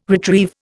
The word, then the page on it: retrieve: Indian